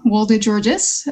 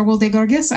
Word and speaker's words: Wolde-Giorgis: But yeah, so my name properly is Ariam Wolde-Giorgis, or Wolde-Giorgis.